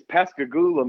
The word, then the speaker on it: Pascagoula: The town I was born in is Pascagoula, Mississippi.